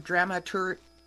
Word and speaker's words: dramaturge: So you're listed as a stage director, dramatic coach, and the last word, dramaturge. Did I say it right? – You did, yes.